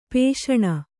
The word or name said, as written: ಪೋಷಣ